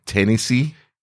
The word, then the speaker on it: Tenysi: Number seven, T-E-N-Y-S-I. Tennessee. – T-E-N-Y-S. Tennessee. So it's just a weird spelling of Tennessee. – Tennessee. – T-E-N-Y-S-I.